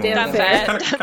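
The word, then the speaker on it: Damphair: Don't forget to pronounce it Dampire.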